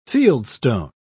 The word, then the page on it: fieldstone: British English